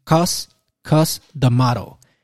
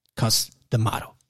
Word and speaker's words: Cus D'Amato: Esta frase se le atribuye a, lo siento, voy a tratar de pronunciarlo bien, Cus D'Amato. Lo estoy pronunciando bien, pero se escucha como que no lo estoy pronunciando bien. Cus, C-U-S, D, apóstrofe, Amato. Cus D'Amato.